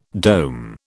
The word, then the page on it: dome: British English